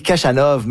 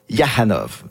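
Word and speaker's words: Khachanov: Là, ça dit Kachanov, mais lui, il prononce Yahanov.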